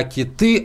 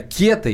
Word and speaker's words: кета: А нам пишут не кеты, а киты, а вот и не, дорогие друзья. Правильно, кеты и только кеты. Вот словарь ударения русского языка дает только один вариант. Кита – это, извините, озеро в Красноярском крае. Вот это кита, а все остальное кета, рыба кета.